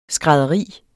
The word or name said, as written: skrædderi